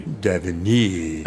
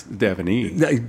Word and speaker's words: Daveny: I checked on it, and he told me how to pronounce it. You ready? – Uh-huh. – Daveny. – I can't believe you couldn't pronounce Daveny. – Daveny. It doesn't look like that on the page.